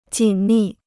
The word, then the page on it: 紧密: jǐn mì